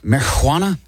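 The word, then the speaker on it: Marihuana: 1936, Marijuana? And I'm saying it that way because it's spelled with an H.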